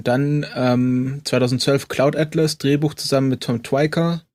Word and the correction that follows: Tykwer: Tykwer, ich glaube, hatte das.